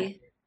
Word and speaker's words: Zappe: So I'm not a huge fan of this, but in their bowl game this past weekend, Bailey Zapp, I think is how you say his name, Western Kentucky's quarterback. Zappy. Yeah, Zappy, broke Joe Burrow's single season record for touchdowns and passing yards.